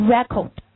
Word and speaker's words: record: 你最重的音落在哪？第一个读 re，对不对？而第二个，刚才我们说，如果要作为另外一种读音，叫 record， record。那么它的重音落在第几音节？第二音节。